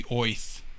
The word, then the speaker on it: Oerth: He says that Orth is actually pronounced as if you had a New York accent. So Orth is supposed to be Oith.